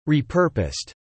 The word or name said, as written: repurposed